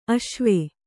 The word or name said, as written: ಆಶ್ವ